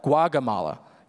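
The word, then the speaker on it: Gaugamela: Not Guatemala, Guagamala.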